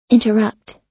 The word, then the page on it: interrupt: American English